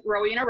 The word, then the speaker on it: Rowena: So we are here today with Rowena Roberts.